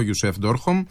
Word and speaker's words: Ντόρχομ: Θα απευθύνουν χαιρετισμούς ο πρέσβης της Παλαιστίνης στην Ελλάδα, ο Ιουσέφ Ντόρχομ, αν τα προφέρω σωστά. Πολύ ο σωστά.